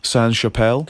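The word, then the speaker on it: Sainte-Chapelle: It was, do I say this correctly, Saint-Chapelle? – Correct.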